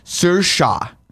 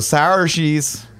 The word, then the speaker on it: Saoirse: Saoirse, that's how you say her first Saoirse. – Name. Ronan. – Yeah, Saoirse Ronan. – But every time I see it, I go, sour cheese.